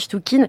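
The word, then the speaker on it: Chtchoukine: Son nom, Sergei Tchoukine, alors c'est compliqué, ça s'écrit Tchoukine, mais moi, j'ai vraiment compris qu'on dit Tchoukine.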